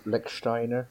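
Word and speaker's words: Lichtsteiner: Help me with the pronunciation. – Lechsteiner, I think he's. – Yeah, that's it.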